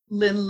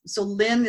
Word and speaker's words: Lyn: And just look up Lynn LeBeau or look up the title of the book, you know, Make Your First $1 Million with Real Estate. Either way, so Lynn is spelled L-Y-N and last name LeBeau, L-E-B as in Bob, E-A-U.